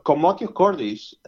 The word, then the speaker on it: commotio cordis: Okay, so commotio cordis, that's a Latin pronunciation. I think that some people pronounce it like commotio cordis.